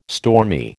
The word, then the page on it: stormy: American English